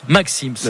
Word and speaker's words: Maxim's: Et chez Maxime se prononce Maxim's.